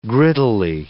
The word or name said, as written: grittily